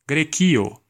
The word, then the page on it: Greece: American English; [ɡriːs]; /ɡɹiːs/